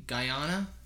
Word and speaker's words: Guyana: A blue tarantula that was found in, I believe, Guyana.